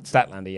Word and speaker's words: Statlander: With Statlander. But no, did I say that right? – Statlander, yes.